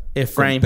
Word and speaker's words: Ephraim: Ephraim es como Efraín, pero. Efraim.